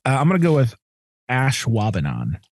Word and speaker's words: Ashwaubenon: It's Ashwaubenon. But okay. If you look at the pronunciation of it, they make it look like ash. We say the A a little bit shorter as Ashwaubenon.